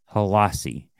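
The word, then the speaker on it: Hailassie: Khalif Halasi. That's how you say it. Halasi.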